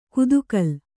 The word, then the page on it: ಕುದುಕಲ್: kudukal